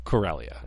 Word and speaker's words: Corellia: Corellia.